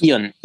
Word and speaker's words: Ion: Et justement, tu, tu parles beaucoup donc de, cette JV avec euh, donc, enfin j'ai peur d'écorcher le nom, c'est Ion, c'est comme ça qu'on prononce. – Ion, oui.